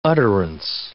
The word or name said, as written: utterance